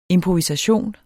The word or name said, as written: IMPROVISATIONER